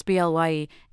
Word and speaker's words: Blaye: 3 years ago, she returned to renovate a chateau they purchased in the town of Blaye, pronounced Blye, in Bordeaux in southeast France.